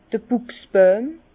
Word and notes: d'r Bùchsbojm: Bas-Rhin; Herrlisheim

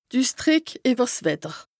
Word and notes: d' Üssdrìck ìwwer 's Wetter: Bas-Rhin; Strasbourg

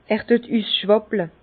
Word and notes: Er düet üssschwàpple: Bas-Rhin; Herrlisheim

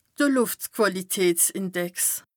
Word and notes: de Lùftquàlitätsindex: Bas Rhin

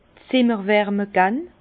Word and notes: Zimmer - Wärme gann: Alsatian; Bas-Rhin; Herrlisheim

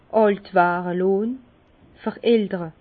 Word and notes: àlt ware lonn, verältere: Bas-Rhin; Herrlisheim

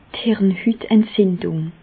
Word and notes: d' Hirnhütentzindùng: Alsatian; Bas-Rhin; Reichshoffen

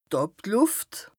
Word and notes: d' Àblùft: Bas-Rhin; Strasbourg

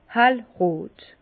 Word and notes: hellrot: Bas-Rhin; Herrlisheim

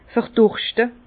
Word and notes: verdurschte: Bas-Rhin; Herrlisheim

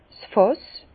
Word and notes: 's Fàss: Bas-Rhin; Herrlisheim

